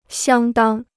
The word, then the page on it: 相当: xiāng dāng